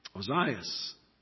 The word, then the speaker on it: Ozias: Ozias is the pronunciation here.